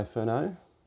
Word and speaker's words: Furneaux: So, I have Kai Furneaux. I did pronounce it right. – You did. – I did. I'm not. That's the French coming out of me.